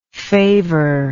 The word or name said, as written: favour